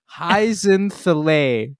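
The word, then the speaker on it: Hyzenthlay: He finds a doe, Heisen Thally. Thally. This is comeuppance for every time I talked about how you always skip a syllable. – Heisen Thale.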